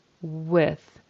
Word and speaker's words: with: And I heard a V-I-Z, V's, or V-I-S, V's. It needs to be a W, W, I, short I, and a soft TH or a unvoiced TH, we would call it, with, tongue between the teeth.